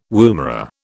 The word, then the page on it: woomera: British English